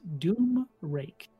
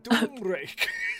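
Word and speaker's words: Domraec: Domrek? – Domrek. D-O-M-R-E-C? – R-A-E-C. – Domrek. – Pronounced Doomrake. – Doomrake.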